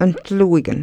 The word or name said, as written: ntluikn